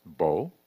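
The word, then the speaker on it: bo (בוא): It's the letters B and then O, which is pronounced bo.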